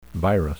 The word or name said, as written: virus